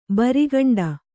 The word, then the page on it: ಬಿರಿಕುಂಟೆ: biri kuṇṭe